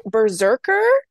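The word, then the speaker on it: BRZRKR: is it pronounced Berserker? – I think so. I Yeah. – think it's supposed to, I don't think you're supposed to spell it out.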